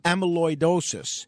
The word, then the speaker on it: amyloidosis: I believe it's pronounced amyloidosis.